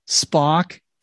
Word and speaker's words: Spock: Dude, Spock rhymes with rock.